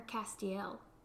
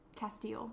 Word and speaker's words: Castiel: Do you say Castile or Castiel? – Castile. – Okay, me too. Okay. Castile.